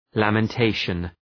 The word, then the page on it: lamentation: ,læmən’teıʃən